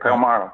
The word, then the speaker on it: Palmyra: How do you say that? Palmyra? Is that right? – That's correct. Palmyra.